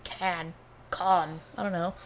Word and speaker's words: Cannes: Cannes. Can. There's no, you don't say the S. I thought it was ES. – Yes, it's spelled that way, but you don't say the ES.